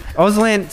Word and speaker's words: Auslan: Auslan, so it's confusing because it's three words, but it's two syllables.